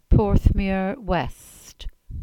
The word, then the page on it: Porthmeur West: Cornish